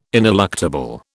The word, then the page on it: ineluctable: British English